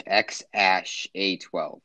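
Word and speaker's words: X Æ A-12: But Musk said his son's name is pronounced X Ash A 12. The X is just X, and the A 12 is just A 12.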